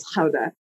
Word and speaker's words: Gouda: It's an Americanization. The correct pronunciation, Celia, is Gouda.